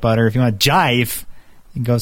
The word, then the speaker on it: GIF: But everyone's concerns are about the pronunciation of the G in the beginning. So, jife. – Nah, no. – No? – It's a gif. Jife. It's always jife.